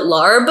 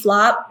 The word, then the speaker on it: larb: Most restaurants will call it larb, mm-hmm. and those restaurants can try to change their menu a little bit because it's a little misleading pronunciation.